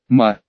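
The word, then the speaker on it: «ма» (马): Это вот этот вот, он читается как «ма». Но не то «ма», которое «мама», это другое «ма». Тоны, помните, как фамилия мамы Вэйлань, уточнила я на уроке.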